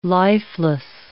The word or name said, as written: lifeless